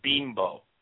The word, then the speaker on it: Bimbo: No, it's the way you pronounce it is Bimbo. It even says it on the thing.